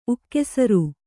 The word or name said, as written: ಉಕ್ಕೆಸರು